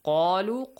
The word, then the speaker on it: قَالُوا: قَالُوا قَاف has a heavy sound.